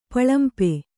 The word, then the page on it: ಪಳಂಪೆ: kannada